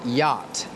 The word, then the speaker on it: yacht: The next word is yacht. This is a difficult word because it has two letters that are silent. The two letters are C and H.